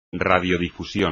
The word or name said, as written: radiodifusión